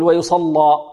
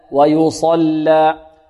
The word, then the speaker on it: ويصلى: ويصلى، ربما البعض يفخم يغلظ اللام لمجاورتها الصاد ويقول ويصلى، لا ويصلى، الياء مرققة والصاد مفخمة لأن من حروف ماذا؟